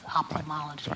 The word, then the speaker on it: ophthalmology: Does the H go silent or does it become a F? – Probably you don't pronounce the F, probably it's ophthalmology.